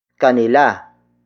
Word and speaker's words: kanila: Word for word, it's tumakbo sa kanila ang dalaga.